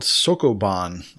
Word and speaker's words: Sokoban: While you were talking, I Googled it, and it's called Sokoban. I'm sure I'm murdering the pronunciation. – No, that looks right or sounds right.